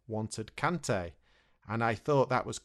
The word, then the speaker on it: Kante: And for Kante, the player, there's an A sound, so we say Kante.